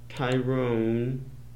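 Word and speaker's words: Tryon: So that's Gene Fowler, and stars Tom Tyrone, that's how we pronounce it, Tom Lester Tyrone, born January 14, 1926 to September 4, 1991, was an American actor and novelist.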